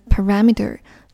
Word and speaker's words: parameter: 接着来看下一个画波浪形的地方，set the parameters for。首先来看这个单词 parameter，重音在第一个 r 的前面，读作 parameter。